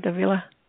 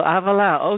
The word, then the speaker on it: Havilah: Do I say your name right, Havilah? – Yeah, Havilah, that's it. – Oh, Havilah, okay.